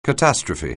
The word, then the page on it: catastrophe: anglais